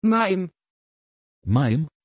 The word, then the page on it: Mayim: Dutch